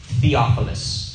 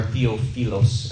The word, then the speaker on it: Theophilus: He says, "Dear Theophilus," or Theophilos, as some might pronounce it.